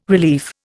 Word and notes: relief: Indian

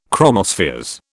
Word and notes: chromospheres: British English

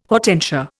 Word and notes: hortensia: Indian

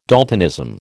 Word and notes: daltonism: American English

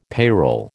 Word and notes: payroll: American English

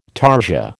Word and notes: tarsia: American English

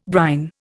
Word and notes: brine: Indian

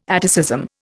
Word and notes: atticism: Indian

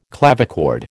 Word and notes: clavichord: American English

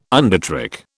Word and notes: undertrick: British English

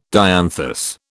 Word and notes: dianthus: British English

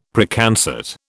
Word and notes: Preconcert: British English

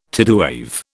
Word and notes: tidewave: British English

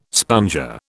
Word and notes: sponger: British English

